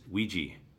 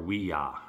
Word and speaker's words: Ouija: The number one movie a couple weeks back was Ouija, or Ouija, however we want to pronounce it. – Ouija.